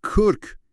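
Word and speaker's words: kırk: مثلا من خودم اوایل سفرم به ترکیه سر قیمت یه فرش تو بازار بزرگ نزدیک بود کرک یعنی 40 رو با دورت یعنی 4 اشتباه بگیرم. – اوه، که میشه 10 برابر. – دقیقاً. تلفظ اون کا تو کرک یکم حلقی تره و این جزئیات کوچیک تو دنیای واقعی خیلی مهمن.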